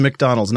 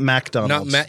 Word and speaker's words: McDonald's: It is McDonald's, not MacDonald's. – Yeah, if it were Mac, it would be MAC. Yeah, mm-hmm. – Yeah, not just MC.